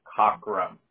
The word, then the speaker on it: Cockrum: My last name is Cockrum. – Spell that for me, please. – Yeah, C-O-C-K-R-U-M.